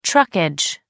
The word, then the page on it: truckage: American English